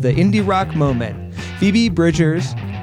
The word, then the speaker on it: Bridgers: But he also did refer to Phoebe Bridgers as Phoebe Bridges, so I don't know if that... – Dude, I looked two different places, and it's Bridgers.